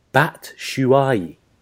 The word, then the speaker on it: Batshuayi: Michy Batshuayi, in case you ever didn't know to pronounce that one. Obviously, the Belgium striker. – Batshuayi.